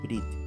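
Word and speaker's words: Brit (ברית): Recientemente, un buen amigo me comentó sobre la forma correcta de pronunciar la palabra pacto en hebreo. Brit, que muchos pronuncian Berit de manera incorrecta.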